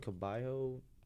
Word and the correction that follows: Cabello: Camila Cabello.